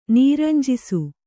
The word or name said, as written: ನೀರಂಜಿಸು